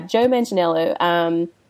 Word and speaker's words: Manganiello: and now we know that Joe Manganiello. I guess that's how you say it. Yeah, Joe Manganiello, who people know from True Blood.